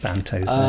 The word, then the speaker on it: Banto Zane: It had Claire Buckfield in it as Sally Ann Stubbins, who was the Banto Zane, who's the fake Doctor, which is apparently it's meant to rhyme with Panto Dame, Banto Zane.